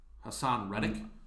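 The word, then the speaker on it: Haason Reddick: Who's Hanson Hedrick? – Hassan Reddick. – What did I say? – Not that. You no. pronounce it 100% wrong.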